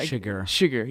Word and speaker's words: Chigurh: Chigurh, yeah.